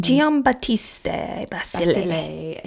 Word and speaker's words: Giambattista Basile: Giambattista Basile.